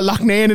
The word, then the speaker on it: Loughnane: Loughnane is from like my part of the world here, and they call it Loughnane in England, but I'm going to continue calling it Loughnane because that's how you pronounce it. He's he's of Irish descent. It's Loughnane. He's the one pronouncing it wrong. I'm pronouncing it right.